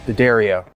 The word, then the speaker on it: D'Addario: And I say it right, D'Addario.